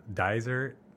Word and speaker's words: Dysart: Dizer, you have to say it with a Z instead of like, because it's pronounced with, yeah, it's spelled D-Y-S-A-R-T. That really is Dizer.